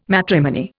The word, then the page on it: matrimony: Indian